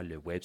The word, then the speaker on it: Ouèbe: Le web s'écrit O-U-E-B-E.